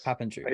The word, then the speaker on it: Papandrew: So without further ado, here is Devon Papandrew. Am I pronouncing that right? – Papandrew, pretty close. – Papandrew.